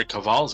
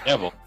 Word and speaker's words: Cavill: I mean, Senator Cavill's got to shave. – Fuck. Why can no one say his name right? – Cavill.